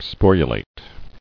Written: Sporulation

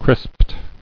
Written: Crisped

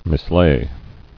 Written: Mislay

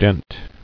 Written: Denting

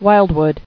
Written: Wildwood

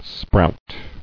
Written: Sprouting

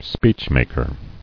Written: Speechmaker

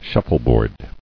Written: Shuffleboard

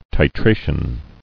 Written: Titration

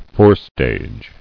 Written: Forestage

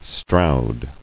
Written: Stroud

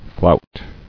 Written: Flouting

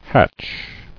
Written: Hatcher

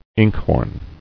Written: Inkhorn

